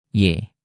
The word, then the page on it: y: Indonesian